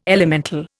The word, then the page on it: elemental: Indian